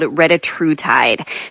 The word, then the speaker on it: retatrutide: The new drug is called retatrutide.